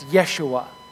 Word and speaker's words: Yeshua: In Hebrew, his name would have been pronounced and written as Yeshua.